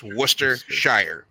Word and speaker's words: Worcestershire: but the city it came from the city of Worcester. It's spelled Worcester, but it's pronounced Worcester. So it's just Worcestershire or Sheer. – Worcestershire. – Worcestershire sauce. – You can say Worcestershire, Worcestershire, or just Worcester sauce.